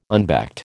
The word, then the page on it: unbacked: American English